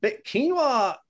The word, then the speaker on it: quinoa: So big quinoa. I mean, I've been on a quinoa kick. Is that how you pronounce it? – Yeah. – Quinoa, that's how quinoa. I pronounce it.